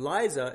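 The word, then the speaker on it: Leise: And Liza is not like Liza Minnelli or Liza Doolittle, but rather Kyrie Eleison.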